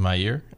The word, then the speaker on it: Meir: Meir, is that how you say it? – Mm-hmm. – Meir.